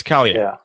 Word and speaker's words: Scalia: They call him Scalia, not Scalia. What's the difference there? – Well, they're pronouncing it wrong.